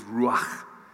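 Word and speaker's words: ruach (רוח): His ruach. And we heard that word last week, which Paul taught us to pronounce his ruach.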